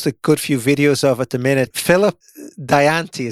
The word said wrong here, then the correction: Dianati: is that how you pronounce his surname? – Uh, Philip Dianati. – Dianati.